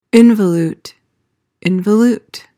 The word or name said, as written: involute